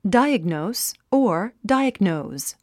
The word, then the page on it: diagnose: dáiəɡnòus; ダイアグノース